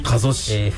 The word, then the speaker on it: 加須市: 加須市、 FM 渡瀬さんですね。